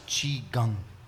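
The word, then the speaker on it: qigong: Herbach smiled knowingly, and Michael Showalter was doing qui-gong and chain-smoking. – It's pronounced chi-gong.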